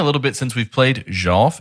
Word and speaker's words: Jolf: And if you're hearing that smooth, buttery pronunciation, like I thought it was called golf. No. This is Jolf. Because it's JJJ. JJJ. – Uh-huh. – OLF.